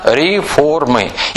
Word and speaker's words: реформы: Я делаю слово ударение «реформация», «реформы». Это будет обязательно «реформы».